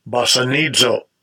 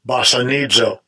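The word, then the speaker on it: Bassanizo (βασανίζω): Bassanizo. Bassanizo.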